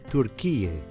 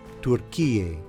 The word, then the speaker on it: Türkiye: With the two dots above the U, it's pronounced U, similar to the sound of U in the English word pure. Türkiye.